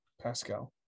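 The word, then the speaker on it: Paschal: Josh Pascal. – Pascal.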